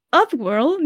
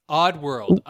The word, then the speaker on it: Oddworld: Upworld, new and tasty. Or new and tasty. – Ooh, I don't know how to say it. – Okay, you nailed it. Okay. – Oddworld.